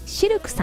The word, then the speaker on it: シルク: そして南青山にフレンチレストランシルクさん、 CILQ と書いてシルクさん、